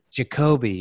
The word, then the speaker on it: Jakobi: Jakobi, yeah.